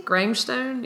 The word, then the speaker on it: Grangestone: So tonight I'm drinking, how do we say it? – Grangestone, maybe? – Grangestone, yes.